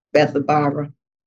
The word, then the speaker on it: Bethabara: And John was baptized in Bethabara, that's how you pronounce that, Bethabara, beyond Jordan, when Christ came and sought baptism.